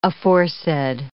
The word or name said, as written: aforesaid